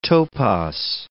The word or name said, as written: topos